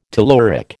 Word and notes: telluric: American English

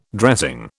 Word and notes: dressing: British English

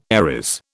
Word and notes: Aries: British English

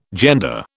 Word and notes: gender: British English

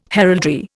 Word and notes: heraldry: Indian

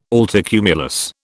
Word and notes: altocumulus: British English

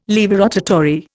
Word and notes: laevorotatory: Indian

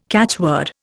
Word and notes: catchword: Indian

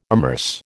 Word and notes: amerce: American English

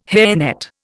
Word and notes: hairnet: Indian

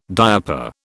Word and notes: diaper: British English

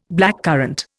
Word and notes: blackcurrant: Indian

